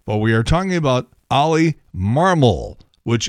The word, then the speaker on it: Marmol: But for some reason, everybody is mispronouncing Ollie Marmol's name. They keep wanting to put the accent on the second syllable instead of the first, and it's wrong.